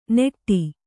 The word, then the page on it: ನೆಟ್ಟಿ: neṭṭi; kannada